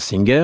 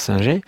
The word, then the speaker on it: Singer: Aux États-Unis, on prononce Singer, en France Singer, n'est pas l'inventeur de la machine à coudre.